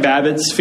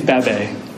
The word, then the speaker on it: Babette: Looks like Babbitt's Feast. But I had to do a Google search. How do you pronounce Babbitt? Because I didn't take French. Apparently it's Babette.